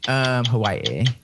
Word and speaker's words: Hawaii: I was like, no, that's not the right pronunciation. Hawaii.